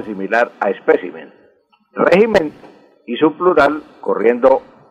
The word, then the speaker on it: espécimen: Don Gilberto, la palabra correcta es espécimen, espécimen con acento en la segunda E, espécimen, y no espécimen.